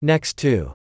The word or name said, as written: Next to